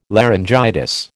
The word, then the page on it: laryngitis: American English